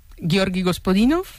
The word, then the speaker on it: Gospodinov: A ver, Georgi Gospodinov, sí.